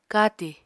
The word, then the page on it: katì: Apatani